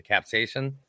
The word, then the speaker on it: capsaicin: It's mainly because of the I don't know if I'm pronouncing it correctly or not, but the capsaicin. – Yeah, that's right.